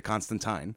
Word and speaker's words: Constantine: And by the way, in the CW show, they do say his name correctly. They say Constantine.